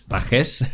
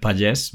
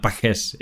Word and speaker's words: Pagés: It's Pagés, although I also accept Pagés because it has a kind of a Catalan and French background, I guess. So that's how you would pronounce it in French or in Catalan. So, but Pagés, it's how I do it.